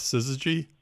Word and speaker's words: syzygy: There's a word that's associated with eclipses I always love, and that's syzygy. – Yes. – Is that the correct pronunciation? – Yes,